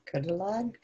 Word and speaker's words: Creiddylad: Credelad. – Credelad. C-R-E-I-D-D-Y-L-A-D.